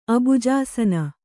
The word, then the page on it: ಅಬುಜಾಸನೆ: abujāsane